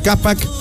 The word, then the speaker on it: Qapaq: Amigo taxista, con Financiera Capac llévate efectivo al toque desde 1000 hasta 5000 soles con tu auto con GNB.